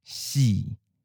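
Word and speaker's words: Xi (四): Xi. Xi. Jit neng sa xi. So xi is considered as an unlucky number in Taiwanese because it sounds similar to the pronunciation as the word for death, xi.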